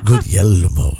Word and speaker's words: Guglielmo: He says, Guglielmo is how you say it.